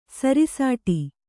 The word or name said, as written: ಸೃಷ್ಟ